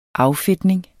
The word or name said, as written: affedtning